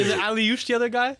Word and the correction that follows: Akliouche: Watch the pronunciation there. If there's two names I want you to remember in that Monaco squad, it's Elias Benzegui and Marnes Akliouche.